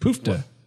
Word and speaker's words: poofter: How do you say it? Poofta. – Poofta. – Poofta. – It's a poofta.